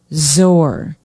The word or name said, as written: zoar